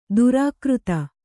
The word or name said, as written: ದುರಾಕೃತ